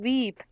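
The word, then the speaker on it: Veep: Vip, lo he dicho mal. Eh, corrígeme. – es que tienes que alargar un poco más la I porque si no es VIP como VIPs el ah, restaurante.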